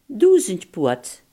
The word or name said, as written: duizendpoot